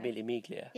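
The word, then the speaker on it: Mille Miglia: How do you pronounce it? The Milli Miglia. – Milli Miglia.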